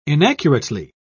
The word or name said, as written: inaccurately